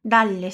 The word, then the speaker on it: dalle: E aqui repara que temos duas consoantes duplas, dalle e alle. Lembra o que acontece com elas? A gente pronuncia de maneira mais demorada, mais alongada, igual o TT da palavra notte, que a gente acabou de ver.